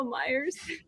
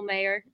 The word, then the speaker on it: Mayer: You know what's so funny about that is I joked on Twitter about all the people calling him Michael Myers, and I feel terrible, Michael Mayer. He's from Covcath, the Cincinnati area. I almost butchered his last name, and he's one of the prospects that I like at 28.